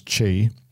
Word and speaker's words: ki: They're wearing their jackets when they're doing yoga as Wade teaches them about ki. I've always pronounced it as chi. It can be pronounced both ways.